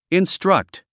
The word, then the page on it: instruct: instrʌ́kt; インストラクト